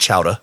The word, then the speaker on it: chowder: And he was from Maine and in his world it's called Chowda because that's how they say it up there.